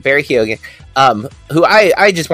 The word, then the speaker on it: Keoghan: Barry Keoghan, which I know that's not how you say his name. – Barry Keoghan. – It is Keoghan. You are correct. – You do hit the O.